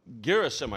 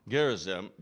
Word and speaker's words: Gerizim: And she said this mountain was Mount Gerizim,